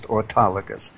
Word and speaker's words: autologous: I won't spell it, but it's pronounced autologous, meaning mm-hmm.